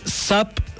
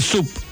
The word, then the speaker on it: SUP: ¿Se dice SAP, SUP o Standard Paddle? Le dicen SUP a secas. Porque por ahí escuché a alguien que decía SAP.